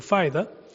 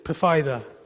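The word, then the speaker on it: Pfaida: We put a P in the front of our name, and it makes our name a bit weird, Pafaida, but the P is silent.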